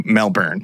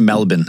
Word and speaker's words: Melbourne: Was traveling on a family trip cross-country from the city of Perth, from where they were from, over to Melbourne, which they would have passed through a number of... – It's pronounced Melbourne, actually. – Melbourne. – They'll make fun of you if you say Melbourne.